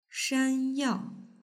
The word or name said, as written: shanyao